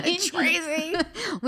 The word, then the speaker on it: Cannistraci: Cannistraci.